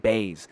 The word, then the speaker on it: Buys: It's spelled B-U-Y-S, but they pronounce it Baez.